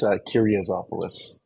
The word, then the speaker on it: Kiriazopoulos: It's Kiriazopoulos.